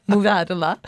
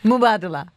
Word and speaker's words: Mubadala: Mubadala. – Did you? Oh no, you mispronounced it, I'd say. – Mubadala. – Oh, that seemed better.